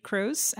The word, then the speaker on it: Croes: It's at Varily Cruz, and that's my last name is spelled Croes.